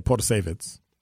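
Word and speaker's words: Protasiewicz: And then on Thursday, we'll have Janet Potashevitz. Or, as they say, Potashevitz. That's the pronunciation guide I had.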